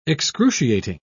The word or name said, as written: excruciating